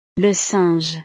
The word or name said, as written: le singe